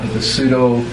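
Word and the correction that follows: Pseudepigrapha: Pseudopigrapha. – Huh? – Pseudopigrapha. – Him. That's what he said. Pseudopigrapha.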